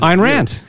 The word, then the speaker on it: Ayn Rand: I learned how to say her name because Ayn Rand.